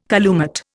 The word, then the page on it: calumet: Indian